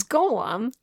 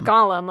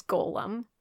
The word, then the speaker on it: Golem: It's Golem. – It is Golem. – It's not Gollum, like Lord of the Rings. – Yeah. – Just in case any of you have been saying it wrong, it's Golem.